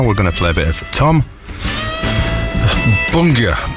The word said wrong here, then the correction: Buenger: B-U-E-N-G-E-R. – I pronounce it Wenger. – We'll go with that. Tom Wenger then.